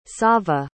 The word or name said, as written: sava